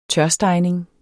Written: tørstegning

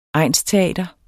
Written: egnsteater

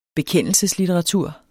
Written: bekendelseslitteratur

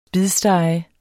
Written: spidstege

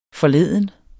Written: forleden

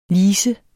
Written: lisens

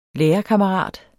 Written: lærekammerat

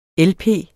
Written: lp

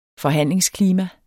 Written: forhandlingsklima

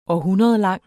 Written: århundredlang

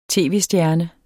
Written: tv-stjerne

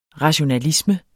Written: rationalisme

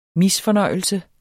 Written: misfornøjelse

